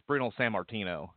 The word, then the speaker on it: Bruno Sammartino: Well, you know, with guys like that pass on, it's Bruno Sammartino is how you pronounce his name.